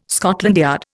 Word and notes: Scotland Yard: Indian

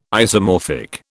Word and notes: isomorphic: British English

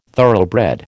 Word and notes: thoroughbred: American English

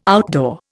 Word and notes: outdoor: Indian

